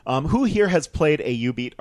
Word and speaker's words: Jubeat: These are 2003's Karaoke Revolution, 2007's Jubeat, and 2010's U-Beat. – Dance Masters. – Careful, the J is silent. Jubeat.